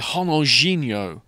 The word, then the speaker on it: Ronaldinho: So Ronaldinho, one of the world's greatest players of his time, is actually Ronaldinho.